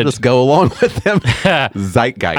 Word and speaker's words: Zeitgeist: Are you saying Zeitgeist? – Zeitgeist. – Did you just completely butcher that? – I did. – That word. – I totally did. Zeitgeist.